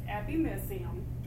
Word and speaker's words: epimysium: The covering or membrane wrapping on the entire outside of your muscle is called the epimyceum. I've also heard it pronounced epimysium.